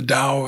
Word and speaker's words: Tao: And read the Tao every day. That's my Bible. – Yep. – Spelled D-O-W, or I'm sorry, T-A-O. – T-A-O. – But it's spelt like D-O-W.